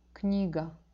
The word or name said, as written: kniga